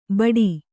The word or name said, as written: ಬಡಿ